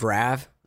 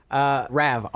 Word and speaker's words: Rav: Rav, R-A-V. That's my new real name. – Like the car, like the Rav 4.